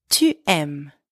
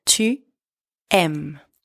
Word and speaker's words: tu aimes: J'aime, tu aimes, you like or you love, tu aimes. And don't forget the silent S at the end.